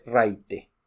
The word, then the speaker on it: raite: Ride. La traducción es aventón, pero nadie, absolutamente nadie en la península de Baja California usa aventón. Se dice raite.